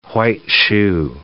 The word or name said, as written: white-shoe